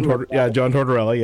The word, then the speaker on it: Tortorella: I did have a plan out to do maybe a tortella. How would you say his name? Tortella. John Tortella. – John Tortorella.